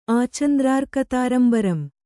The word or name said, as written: ಆಚಂದ್ರಾರ್ಕತಾರಂಬರಂ